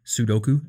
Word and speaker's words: Sudoku: I saw this girl next to me playing Sudoku, and I don't even know if I pronounced that word right. Sudoku, I think it is.